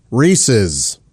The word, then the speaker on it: Reese's: Although the correct pronunciation of the candy is Reese's.